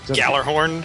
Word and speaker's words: Gjallarhorn: In this episode, right after the battle that concluded episode 5, how do you pronounce this? The Galahorn or the Jalahorn? – Gjallarhorn. – Gjallarhorn. Thank you.